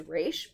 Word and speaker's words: Ra's: Arguably maybe the original, who clearly is supposed to be Ra's al Ghul, which by the way is spelled R-A-apostrophe-S and should be pronounced Raish, but like it is very hard for people to swallow that, so we just say Ra's al Ghul.